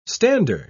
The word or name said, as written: stander